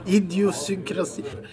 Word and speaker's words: idiosynkrasi: Idiosynkrasi. – Men vänta nu. Säg bara stavningen. Tyst. – I-D-I... O-S-Y... N-K-R-A-S-I. – Ja. – Betoning på i till slutet.